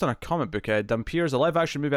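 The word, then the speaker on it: Dampyr: Dampire. I'm saying Dampier because it's spelt with the Y like vampire.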